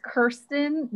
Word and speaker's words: Kirsten: It's just like a common, it's Kirsten, not Kristen or Kirsten.